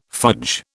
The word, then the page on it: fudge: British English